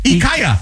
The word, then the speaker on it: IKEA: I've been saying it wrong? – Yeah. – This says it's IKEA. IKEA.